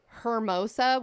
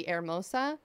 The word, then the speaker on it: Hermosa: And as an example, I'll say we have a place called Hermosa, which technically should be Hermosa. – Herbrosa. – Yeah, exactly. So there's a lot of different cities, different suburbs around the LA area that are technically pronounced incorrectly.